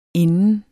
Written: inden

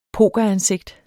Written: pokeransigt

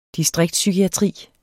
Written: distriktspsykiatri

